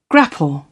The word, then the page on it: grapple: British English